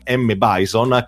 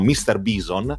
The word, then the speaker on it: M. Bison: M. Bison, che molti pronunciavano, almeno molti amici che conoscevo, pronunciavano Mr. Bison,